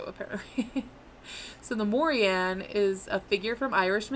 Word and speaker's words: Morrigan: Morian it's spelled Morrigan, but I've read that the G is silent, so I'm gonna say Morrigan. Morian.